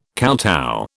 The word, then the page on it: kowtow: British English